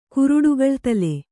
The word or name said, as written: ಕುರುಡುಗಳ್ತಲೆ